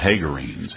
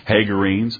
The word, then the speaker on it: Hagarenes: Hagarenes. It's a long A. Hagarenes. Take a look here in 1 Chronicles 5.